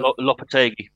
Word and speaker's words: Lopetegui: Lopetegui.